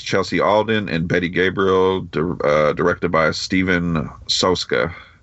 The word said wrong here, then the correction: Sosko: No, I'm sorry, mispronunciation. Sosko.